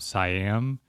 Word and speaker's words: Siam: The conjoined twins that came from Siam, which is how it's pronounced, that is now Thailand.